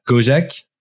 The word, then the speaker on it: Gojek: You were the senior VP of growth and data at Gojek. Is it the right way to pronounce it? Yes? – That's right. – That's correct.